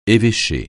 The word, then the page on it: évêché: français